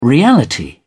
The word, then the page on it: reality: British English